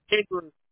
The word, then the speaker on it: Andrews: Andrew. – Got it. Spell it for me, please. – A-N-D. R-E-W-S.